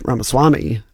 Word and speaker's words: Ramaswamy: Almost all the Democratic ads against Ramaswamy have been negative. Just to make sure, his name is Vivek Ramaswamy. Acton mispronounced that.